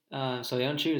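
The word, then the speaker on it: Söyüncü: I would take Soyuncu, is that how you say it? – Yeah.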